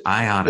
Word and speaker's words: IATA: IATA. Is that really how you pronounce it? IATA. – It is. Yeah, IATA.